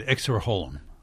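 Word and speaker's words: Exserohilum: And I'm not sure how to pronounce it, the Exerohilum fungus? – Yeah, well, that's pretty good. Exerohilum.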